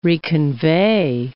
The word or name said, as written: reconvey